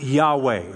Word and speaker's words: Yahweh: We would call it YHWH, and we think it's pronounced Yahweh. It's rendered as Yahweh.